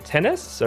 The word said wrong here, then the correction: Tunis: Or it's Jenny, oh my gosh, Jesse Tunis.